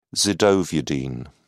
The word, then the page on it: zidovudine: British English